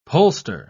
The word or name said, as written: pollster